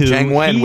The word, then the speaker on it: Jiang Wen: Jiang Wen, which kind of rhymes with Donnie Yen. Yen and Wen.